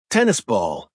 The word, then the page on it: tennis ball: American English